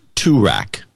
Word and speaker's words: Toorak: That's Gavin Warren, uh, and it's pronounced Toorak is the name of the town.